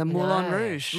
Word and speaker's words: Moulin Rouge: Exactly how you said it, the Moulin Rouge. – Maurice says, I've spent a lot of time in France. I have a French-speaking wife and I'm constantly reminded that the correct way is Moulin. Moulin Rouge, of course, oui.